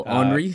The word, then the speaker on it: Henri: Just because I don't want Guillaume to lose his francophone card, I think it's not Henry, because it's spelled with an I. – Oh, Henri? – Charles Henri.